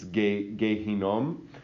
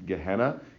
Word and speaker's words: Gehinom: In Hebrew, it's Gehinom. Sometimes people pronounce it Gehenna, Gehinom.